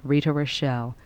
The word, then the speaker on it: Rita Rochelle: To get a copy, just write to me, Rita Rochelle. My name is spelled R-I-T-A. Rochelle is R-O-C-H-E-L-L-E.